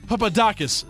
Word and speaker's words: Papadakis: So, our Slam and Sue Bang of the Week is Gabriella Papadoukas. I got that French name, no problem. But I can't say a goddamn Russian name. Gabriella Papadakis, yeah, I said it right.